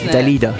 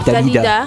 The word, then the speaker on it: Dalida: It's called, the artist called Dalida. – Dalida. – She's French. – That name is just beautiful in itself. – It is Dalida. You have to say it with a French accent. Dalida.